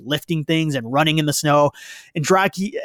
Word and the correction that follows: Drago: and Dragi, I'm going to pronounce his name correctly here and Drago is taking steroids and training with high tech equipment.